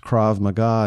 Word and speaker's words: Krav Maga: And he says, Well, it's pronounced Krav Maga.